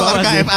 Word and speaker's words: archive: Arsive? – Aduh. – Bener enggak? – Bener enggak? Bener enggak? – Bener gak, teman-teman? – Salah. – Salah. – Yang bener apa dong? – Iya, bener sih. – Betul, archive. Ada sih tulisannya sih, bener sih.